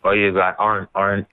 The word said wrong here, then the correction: Arenado: How do you pronounce his name in Colorado? – Oh, Arenado. Oh my goodness.